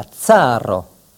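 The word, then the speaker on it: caro: C se prononce toujours ts. Exemple, la tsaro, le tsar.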